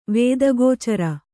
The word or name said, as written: ವೇದಗೋಚರ